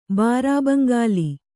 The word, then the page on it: ಬಾರಾಬಂಗಾಲಿ: bārābangāli